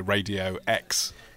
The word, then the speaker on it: EXE: So it's spelt E-X-E, but it's said, of course, because it's Exeter Radio X.